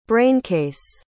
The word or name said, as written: braincase